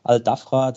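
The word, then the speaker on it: Al Dhafra: Ähm, du hast noch Al-Dafra erwähnt, hast du richtig ausgesprochen. Al-Dafra, das ist die gesamte westliche Region Abu Dhabis mit der gesamten Küstenlinie.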